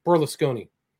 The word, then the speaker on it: Berlusconi: Berlusconi.